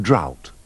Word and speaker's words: drought: Don't pronounce the GH in drought.